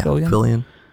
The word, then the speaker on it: Fillion: Fillion? How do you say his name? – Yeah, Fillion.